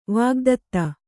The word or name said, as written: ವಾಗ್ದತ್ತ